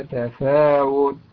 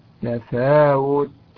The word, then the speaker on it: تفاوت: أيضا في مثل قوله تعالى: تفاوت تفاوت، انتبه إلى ضم الواو.